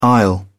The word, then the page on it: aisle: British English